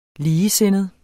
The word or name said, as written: ligesindet